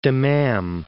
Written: Dammam

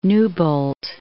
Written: Newbolt

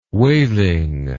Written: waling

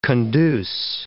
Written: conduce

